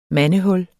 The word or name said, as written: mandehul